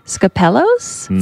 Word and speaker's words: Skopelos: is it Skopelos? – Sounds right.